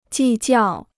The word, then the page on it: 计较: jì jiào